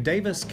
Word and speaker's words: Daviess: Then for high school, she went to Davis County High School, that's spelled D-A-V-I-E-S-S, which was then located on the east side of Owensboro.